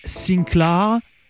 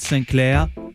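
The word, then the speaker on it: Sinclar: Please pronounce it Sinclair, not Sinclair. You used to hear French accent with Antoine de Caunes, Jean-Paul Gaultier and Thierry Henry.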